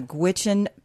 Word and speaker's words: Gwich'in: It's the Gwich'in people? – Right.